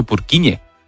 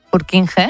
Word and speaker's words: Purkinje: Es que se escribe, en español sería Purkinje. – Purkinje. – Pero Purkinje es en checo, que es por porque es el apellido del descubridor,